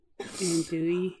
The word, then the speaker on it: andouille: I did a shrimp, I never say it right, a dooley sausage. – Andouille.